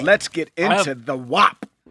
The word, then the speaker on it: WAP: We have to talk about the WAP. Is that how you say it? WAP? – Yeah, no, you got it right.